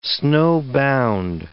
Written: snowbound

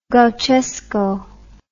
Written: gauchesco

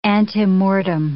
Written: antemortem

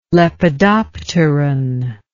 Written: lepidopteron

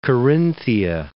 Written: Carinthia

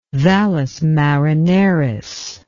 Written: Valles Marineris

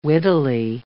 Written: wittily

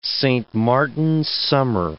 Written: Saint Martin's summer

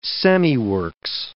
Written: semiworks

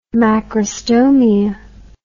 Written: macrostomia

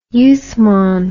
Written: Uthman

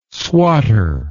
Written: swotter

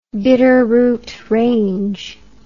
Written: Bitterroot Range

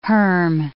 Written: herm

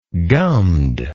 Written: gummed

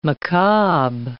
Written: macabre

